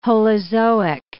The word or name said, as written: holozoic